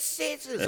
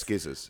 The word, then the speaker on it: scissors: It's scissors. So like, how do you spell it? S-C. Yeah. Exactly. – Oh, no. Yeah. Yeah, you're right. – Oh yes, skizzers. Every single kid in my class, by the end of the year, would just say skizzers.